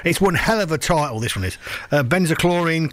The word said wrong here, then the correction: Benzalkonium: chloride or something like that. Anyway, it's not quite like that. I can't pronounce it. Benzalkonium. There we go.